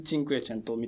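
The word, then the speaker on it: Cinquecento: チンクエチェントみたいになっていて、で、個人的には、そのクラシックの現代版を作るっていう意味では、チンクが一番うまくやってる気がする。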